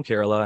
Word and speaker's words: Kerala: But I looked it up, or actually somebody from Kerala, India. It's like, hey, I'm from Kerala and it's actually pronounced this way. And I like Googled it and sure enough, it's spelled K-E-R-A-L-A. So it looks like Kerala, but it's Kerala.